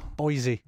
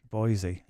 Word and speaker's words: Boise: Is it Boise Open or Boise? – Yes, Boise. – Boise. – Yes, that's Boise.